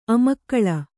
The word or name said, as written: ಅಮ್ಮಿಕಲ್ಲು